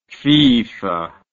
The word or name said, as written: FIFA